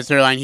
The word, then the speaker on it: Zierlein: So that was Zierlein? I don't know how to pronounce his last name. Yeah, Zierlein.